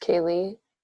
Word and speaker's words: Kai-Lee: I know it's spelled really odd, but it's pronounced Kaylee, and then my last name is Worsley.